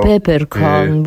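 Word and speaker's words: Peeperkorn: Bardzo ciekawą postacią jest Pipperkorn, z którym Klaudia powraca do... – Albo Pepperkorn, bo to jest przecież Holender.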